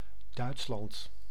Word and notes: Duitsland: NL; /ˈdœʏ̯tslɑnt/

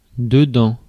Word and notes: dedans: France; [də.dɑ̃]

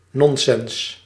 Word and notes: bull: bʊl

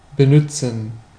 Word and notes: benützen: /bəˈnʏtsən/

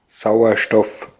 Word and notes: oxygen: American English

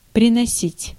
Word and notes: приносить: /prʲɪnɐˈsʲitʲ/; prinosit

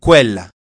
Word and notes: denna: /ˈdèna/

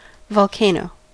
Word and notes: volcano: American English; /vɑl.ˈkeɪ.noʊ/

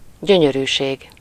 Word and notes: plaisir: /ple.ziʁ/; /plɛ.ziʁ/

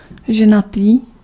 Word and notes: married: /ˈmæɹ.ɪd/

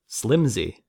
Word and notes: frêle: /fʁɛl/

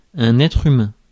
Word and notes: être humain: /ɛ.tʁ‿y.mɛ̃/; France

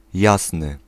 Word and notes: jasny: [ˈjas̪n̪ɨ]

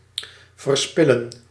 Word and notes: prodiguer: France; [pʁɔ.di.ɡe]

